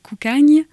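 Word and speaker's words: Cocanha: J'étais ravie parce qu'on entend le duo Coucagne. Je ne sais pas si je l'ai bien prononcé, Oui, c'est bien comme ça.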